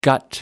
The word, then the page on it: gut: American English